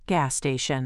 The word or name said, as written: gas station